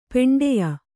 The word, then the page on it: ಪಿಂಡಾಯು: piṇḍāyu